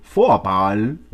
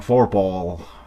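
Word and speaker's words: Vorphal: Y, se, y se ven los apellidos, hay unos apellidos que son alemanes hay una chica que es de apellido Foapal, ¿ya? Con, con V, ¿ya? V-O-R, Foapal, ¿ya? P-H-A-L. Y bueno, pues así en gringo lo pronuncian Forpal. Forpal, Foapal es una, eh, es, una, es un apellido alemán.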